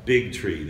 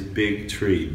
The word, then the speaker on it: Bigtree: My name is Del Bigtree. That's first name D-E-L. My last name is Bigtree. B-I-G-T-R-E-E.